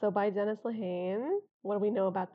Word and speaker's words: Dennis Lehane: about a month ago, we finished Gone, Baby Gone by, Dennis Lehane. – and I'm asking her to say it because I don't know how to pronounce his last name, so I was probably going to say Denise Lehane.